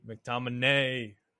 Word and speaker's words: McTominay: McTominay.